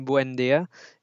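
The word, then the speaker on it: Buendía: Buendia, am I pronouncing that right? – Yeah, Emi Buendia.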